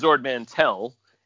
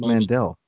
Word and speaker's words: Ord Mantell: It was in the script as Ord Mantell, and documentation, the novelization, the comic book, and everything had the T. And so Harrison Ford just pronounced it differently, and they never ADR'd it because no one cared.